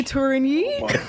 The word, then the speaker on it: Tourigny: I'm going to try my best with the pronunciation of your last name, Andre Tourigny.